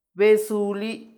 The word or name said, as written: besuli